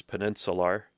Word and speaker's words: peninsular: And no, I didn't mispronounce peninsular in the 2020 edition. That is how it's spelled. Peninsular, with an R, countertops.